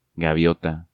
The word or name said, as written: Gaviota